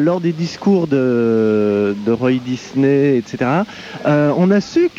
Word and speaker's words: Disney: parce qu'en anglais on dit Disney, Disney.